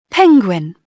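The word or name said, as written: penguin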